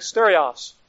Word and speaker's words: stereos: We would see it, we would say stereos, pronounced something like stereos.